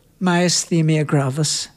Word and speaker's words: Myasthenia gravis: Myasthenia gravis.